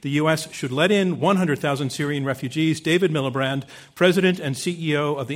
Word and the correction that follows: Miliband: I pronounced his name wrong. What did I say? What did I say? – Miliband. – I put the R in?